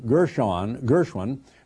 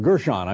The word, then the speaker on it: Gershon: Gershon, who is the, or Gershon, I should say, is the correct pronunciation of his name,